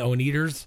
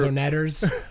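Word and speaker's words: Oneders: Yeah, it was the Wonders, but they kept saying the Oneaters. – Yeah, it was cool. He was like the cool one. – It's pronounced Onetters.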